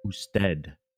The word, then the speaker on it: usted: Usted.